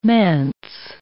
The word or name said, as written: manse